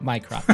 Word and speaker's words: Mycroft: Not Minecraft, but Mycroft.